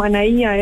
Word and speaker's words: Anaya: Me llamo Anaía Eder. Y en Instagram es A-N-A-Y-A-Eder. Aeder se escribe A-T-H-E-L.